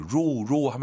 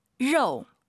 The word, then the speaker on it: 肉: 로로 하면 안 돼요, 여러분. – 肉. – 왜 로우라고 하시죠? 오유 발음은 어우니까.